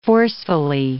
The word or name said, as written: forcefully